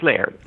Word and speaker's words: Layard: Maybe I'm saying his name wrong. Is it Layard? – It's Layard.